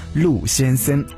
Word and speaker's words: 陆先森: 这首春风十里来自陆先森乐队，不是我发音不准，是名字就是陆先森。